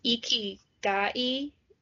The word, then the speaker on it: ikigai: It's pronounced ikigai. It's spelled I-K-I-G-A-I.